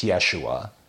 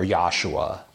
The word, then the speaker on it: Yeshua: But it there's like no J. No. It's a Yod. It's Yeshua. Yeah, or Yahshua.